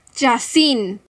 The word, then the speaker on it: Jacin: Ella pronuncia Jacin porque dice que suena mejor que Jason.